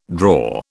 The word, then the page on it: drawer: British English